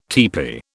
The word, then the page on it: tepee: British English